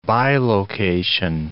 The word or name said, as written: bilocation